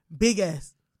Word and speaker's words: Big S: Big S. – Emphasis on what? – Emphasis on the S.